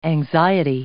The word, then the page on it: anxiety: noun